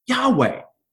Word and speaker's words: Yahweh: If they hadn't given us that, we might not have known how to pronounce the word Yahweh. Right? Because it's actually in the text, it's just YHWH, right? But what they gave us was the ability to pronounce it Yahweh.